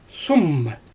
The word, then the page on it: suum: Xyzyl